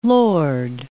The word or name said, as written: lord